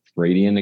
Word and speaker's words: Vradian: Why don't we start with your Vradian? Am I pronouncing that correct? Vradian Academy series. Mm-hmm.